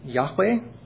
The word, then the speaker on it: Yahweh: But in the we would pronounce it Yahweh. It comes from four Hebrew consonants that have no vowels. But when we fill them in with presumed vowels, it would be something like Yahweh.